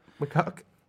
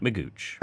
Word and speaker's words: McGeoch: Unfortunately, the pronunciation I chose to go with sounded like Magooch.